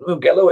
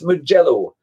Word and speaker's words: Mugello: And on that note, Mugello. Now, I think I'm pronouncing that correctly. I know if Andrew was here, my co-host, he'd be saying, it's not Mugello, it's Mugello. Now,